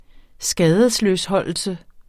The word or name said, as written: skadesløsholdelse